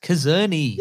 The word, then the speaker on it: Czerny: Henry Czerny. No. Henry Czerny. – Mission Impossible 1 cast, because I can't spell that, because you didn't say it right. – It's Czerny.